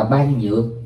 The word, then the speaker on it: bát nhã: Rồi chữ bát nhã có chỗ đọc là ban nhược.